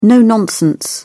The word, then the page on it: no-nonsense: British English